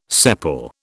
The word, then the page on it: sepal: British English